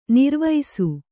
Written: ನಿರ್ವೈಸು

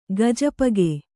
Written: ಗಜಪಗೆ

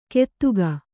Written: ಕೆತ್ತುಗ

